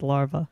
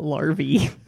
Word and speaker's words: larvae: Of I think it's just larvae. Not Larvey. – Doesn't rhyme with Harvey? – Larvey Dent.